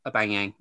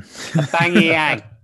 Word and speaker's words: Aubameyang: Maybe not even start Aubameyang.